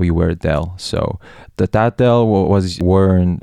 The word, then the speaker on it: deel: So our traditional costume is called dil. So in English, it's spelled D-E-E-L, so D-E-E-L, and it's kind of like a robe.